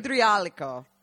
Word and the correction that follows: idraulico: Idraulico. – Idraulico.